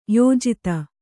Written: ಯೋಜಿತ

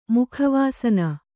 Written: ಮುಖವಾಸನ

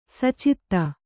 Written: ಸುಚಿತ್ತ

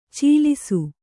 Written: ಚೀಲಿಸು

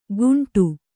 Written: ಕುಂಟು

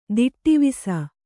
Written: ದಿಟ್ಟಿವಿಸ

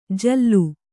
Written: ಜಲ್ಲು

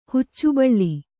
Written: ಹುಚ್ಚುಬಳ್ಳಿ

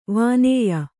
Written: ವಾನೇಯ

